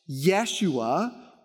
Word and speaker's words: Yeshua: The name Jesus is in Hebrew pronounced Yeshua or Yahoshua.